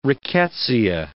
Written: rickettsia

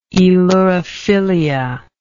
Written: aelurophilia

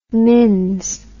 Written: mins

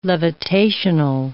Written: levitational

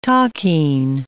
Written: takin

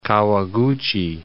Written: Kawaguchi

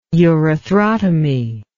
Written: urethrotomy

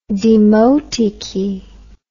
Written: demotiki

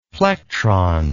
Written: plectron

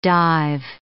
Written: dive

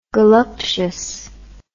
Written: goluptious

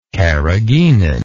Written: carrageenan

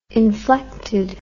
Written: inflected

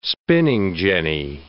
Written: spinning jenny

